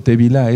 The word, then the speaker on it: tevilá: Aquí explico, tevilá, eso significa, eh, eh, así se pronuncia en hebreo, tevilá, la inmersión en agua en el nombre de nuestro don Yahshua Mashiach.